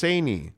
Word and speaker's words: Sinai: Greg Romano says, Sinai is pronounced Saini. Oh, is it? Okay, Saini.